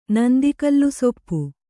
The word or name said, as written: ನಂದಿಕಲ್ಲುಸೊಪ್ಪು